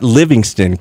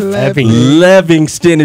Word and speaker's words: Levingston: But Cliff Levingston. Say it right, not Livingston. Cliff Levingston is in the building, fellas.